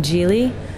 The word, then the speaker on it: gyil: The West African djili. It's spelled G-Y-I-L.